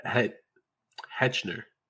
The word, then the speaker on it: Hechinger: But we got some news that Fred Heckinger. I don't know how to say his name. But Fred Hechinger. There we go. Yeah, that one. Hechinger.